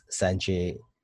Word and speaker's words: Sanjay: My full name is Prince Sanjay Saha, PRINCE SANJAY, and last name SAHA.